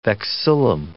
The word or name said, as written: vexillum